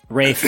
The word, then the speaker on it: Ralph: Ralph Fiennes villains for two. Rafe. I'm sorry. It's Rafe. That's how it's Fiennes? – Pronounced.